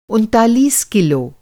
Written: उनतालीस

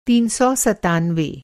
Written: तीन सौ सत्तानवे

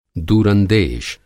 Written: दूरंदेश

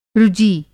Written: रुजी

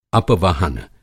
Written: अपवहन

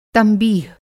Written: तंबीह

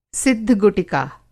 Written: सिद्ध-गुटिका

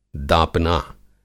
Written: दापना

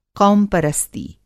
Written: क़ौम-परस्ती

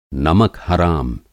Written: नमक-हराम

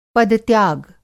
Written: पद-त्याग

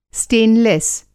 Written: स्टेनलेस

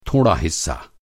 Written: हिस्सा

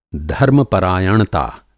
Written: धर्म-परायणता

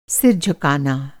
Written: झुकाना

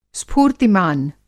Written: स्फूर्तिमान

